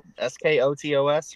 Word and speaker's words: Skotos: S K O T O S, right? It is spelled S K O T O S.